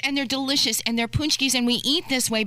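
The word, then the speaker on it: pączki: But they call them poonchkis. It doesn't look like it's spelled.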